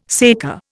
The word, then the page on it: saker: Indian